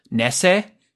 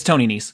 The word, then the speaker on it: Nese: After that, we got Jake Atlas versus Tony, I believe it's pronounced Nese. It's Tony Nese.